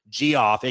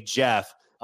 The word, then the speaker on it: Geoff: And one of the main characters, G-off, aka Jeff, it is spelled G-E-O-F-F, is just an absolute badass.